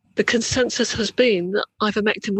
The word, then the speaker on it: ivermectin: Ivermectin, yeah.